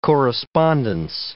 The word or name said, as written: correspondence